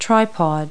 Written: tripod

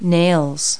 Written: nails

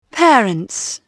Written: parents

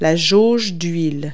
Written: dipstick

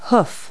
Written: hoof

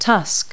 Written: tusk

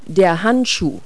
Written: glove

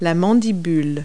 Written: mandible

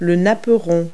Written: placemat

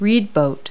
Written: reed boat

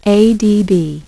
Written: ADB

